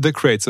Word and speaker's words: Cratez: so, ähm, ich habe es richtig ausgesprochen, ne? The Crates, oder? – Genau, ja, genau, ja. ist richtig. Genau.